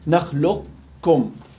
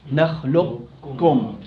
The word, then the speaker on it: نخلقكم: نخلقكم نخلقكم نخلقكم. لا فيش قلقل.